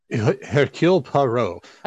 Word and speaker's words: Hercule Poirot: And Hercule Poirot, I can't say his name. Is it Hercule? – Hercule Poirot.